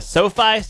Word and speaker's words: SoFi: The Jimmy Kimmel LA Bowl will take place on December 18th at SoFi Stadium.